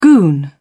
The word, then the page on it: goon: British English